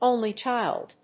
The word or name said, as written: only child